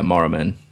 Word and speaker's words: Maruman: And I got again, can't pronounce it. Maruman. – Yeah, Maruman.